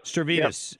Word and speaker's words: Stavridis: Stavridis.